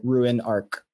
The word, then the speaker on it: Ruin Arc: So it's like ruin, like you're ruining something, and monarch. So like Ruin Arc.